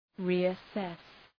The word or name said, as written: reassess